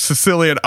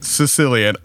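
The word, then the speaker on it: caecilian: So a group of scientists from Brazil that were studying a worm-like amphibian called the Sicilian, and I promise you, I looked up that pronunciation. It is Sicilian. I am not the one that is making that link phonetically or culturally. – It's a C-A-E. – It is C-A-E, but it is pronounced Sicilian.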